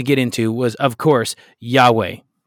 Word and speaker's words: Yahweh: Yahweh, which is how we pronounce it now.